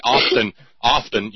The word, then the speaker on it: often: Now Todd's into it because everybody knows it drives me crazy when people say often, you know, O-F-T-E-N, you know, where you with the silent T, often.